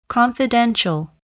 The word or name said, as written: confidential